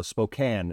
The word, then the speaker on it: Spokane: So Spokane is how it's properly pronounced.